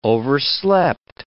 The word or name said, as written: overslept